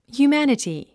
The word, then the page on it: humanity: hjuːmǽnəti; ヒューマナティ